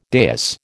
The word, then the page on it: dais: American English